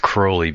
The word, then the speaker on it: Crowley: He preferred his name to be pronounced Crowley because it rhymed with holy.